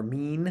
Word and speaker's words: Min: There was Min, or Mean, I think's how you say it, M-I-N, deity of the harvest.